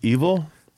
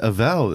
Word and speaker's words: Evel: Oh, it's pronounced evil? – What did you think it was? – I've been saying Avow this whole time, yeah.